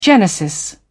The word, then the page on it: genesis: British English